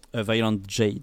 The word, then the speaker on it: Violent J: Violent J, d'ailleurs. C'est un J. Ça se prononce J en anglais.